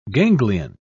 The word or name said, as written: ganglion